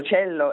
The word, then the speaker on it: Cello: Cello, è un violoncello. – Certo, quindi pronuncia non all'inglese, diciamo.